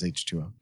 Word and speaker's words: H2O: I didn't know if like it was H20 or H2O. – Well, it was the 20th anniversary, but yeah, it was H2O.